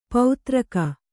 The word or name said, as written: ಪೌತ್ರಕ